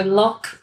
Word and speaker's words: Loch: So Loch, or how do you say it? – Loch.